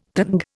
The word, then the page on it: tanga: Indian